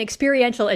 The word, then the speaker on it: experiential: Yeah, I have a master's degree in experiential education. – There we go. – So, you said it. Yeah, it's tricky and frequently called experimental education, which is not quite right, but kind of makes it sound a little spicier.